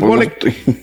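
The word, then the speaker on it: Kwolek: Eh, dos, las sombreras, Stephanie Locke. No, ¿cómo, se pronuncia? Bolek. Bueno, Stephanie Wolek química que trabajaba para DuPont, sintetizó una polimida ligera y resistente llamada Kevlar en 1965.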